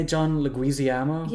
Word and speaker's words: Leguizamo: John Leguizamo. Is that how you say his name? – Yeah, John Leguizamo.